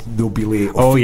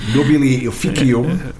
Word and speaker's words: nobile officium: Classical Latin would have been nobile officium, but Church Latin would be nobile officium.